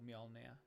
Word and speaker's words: Mjolnir: It's called Mjolnir.